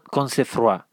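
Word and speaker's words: Cosnefroy: el final fue una fuga en la que el ganador de ese sprint dentro de la fuga fue el francés Benoit Concefroy. Creo que esa es la pronunciación más correcta en francés.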